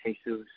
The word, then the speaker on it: Jesus: Is it a Y sound at the beginning? Or H? – It's an H. H. – H. Jesus. – In English, Jesus, yeah.